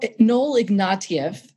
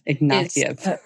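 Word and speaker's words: Ignatiev: Noel Ignatius, you talk about him. Why is he important? – Well, Noel Ignatieff. – Ignatieff.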